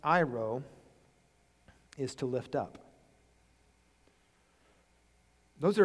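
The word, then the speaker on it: Iro (αἴρω): This word that is translated into English, takes away, the Greek word is spelled A-I-R-O, Iro.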